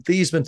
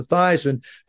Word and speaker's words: Theismann: We're not even going to count on the reputation of the University of Notre Dame, but we think by just simply changing the pronunciation of your last name from Theismann to Theismann to rhyme with Heisman, we can get you that trophy.